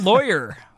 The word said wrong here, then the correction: Lawler: Lawler. Fergal Lawyer. – Lawler. Lawler. I always screw up these names.